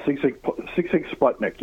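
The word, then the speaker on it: Sigue Sigue Sputnik: Yeah, Sig Sig Sputnik, yeah.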